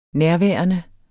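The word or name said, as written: nærværende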